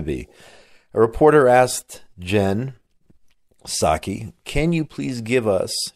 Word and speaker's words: Psaki: I just learned how to pronounce her name, by the way, even though it's spelled P-S-A-K-I.